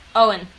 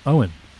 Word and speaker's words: Eoin: how would you pronounce a name which is E-O-I-N? – Owen. – Owen.